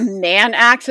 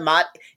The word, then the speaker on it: Mann Act: So it's not a moat. It's not a mot. It's the Mann Act. M-A-N-N Act.